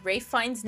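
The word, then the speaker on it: Ralph Fiennes: Rafe Fiennes' name is spelled neither Rafe nor Fiennes. Discuss.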